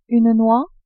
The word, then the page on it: noix: French